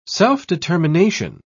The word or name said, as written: self-determination